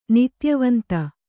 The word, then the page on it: ನಿತ್ಯವಂತ: kannada